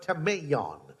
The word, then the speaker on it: tameion: Uh, so how, the way you spell it is by spelling the word tame, T A M E. All right, tame. All right, uh, eon, I O N, tameion.